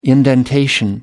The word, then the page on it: indentation: American English